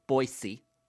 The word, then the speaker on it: Boise: I'm going to go up to Boise, Idaho, or as JT tells me to speak clearly, Boise. That's how you say it.